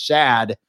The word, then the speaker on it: Schad: And then people call me Rashad, even though it's pronounced Shad.